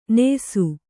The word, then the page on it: ನ್ಯಾಸಿ: nyāsi